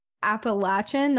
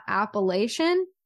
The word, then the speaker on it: Appalachian: Um, and I actually just recently, within the past, well, I don't know, I guess when I was in college, figured out that it's Appalachian, not Appalachian.